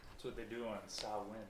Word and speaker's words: Samhain: You're saying Sam Hain? It's pronounced Samhain, actually.